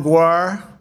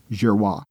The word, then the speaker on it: Giroir: Brett Girois, or as Donald Trump calls him, Admiral Guar. – Here was Admiral G-I-R-O-I-R, pronounced Girois.